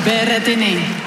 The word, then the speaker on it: Berrettini: Berrettini.